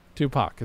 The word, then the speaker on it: Tupac: Tupac. It's pronounced Tupac. Tupac. Yes, Tupac. Thank you.